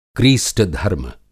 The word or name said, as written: क्रीस्टधर्म